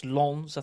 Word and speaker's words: Lens: So Group B, we've got Sevilla, Arsenal, PSV, and Lens, pronounced Lens, I think, if you're sophisticated, but spelt Lens.